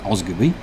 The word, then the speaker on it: Osgodby: Osgodby, that's O-S-G-O-D-B-Y, pronounced Osgoby, is a village name in East Yorkshire, North Yorkshire, and Lincolnshire.